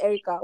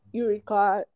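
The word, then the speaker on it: Erykah: Oh, her name is Erica.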